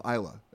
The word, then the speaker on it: Islay: Now, you heard me mention some of those brands that come from the Isle of Islay. That's Islay, but pronounced Isla.